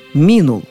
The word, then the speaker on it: минул: Однако день минул, ночь минула, годы минули, время минуло. То есть ударение переходит со второго слога на первый во всех формах.